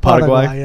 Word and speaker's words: Paraguay: Paraguay, how do you say it? – Paraguay, yeah.